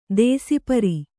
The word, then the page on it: ದೇಸಿಪರಿ: dēsi pari